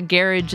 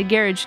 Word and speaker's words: garage: So we saw John Fratelli at the Garage in London. And I say Garage because that's how they say it over there.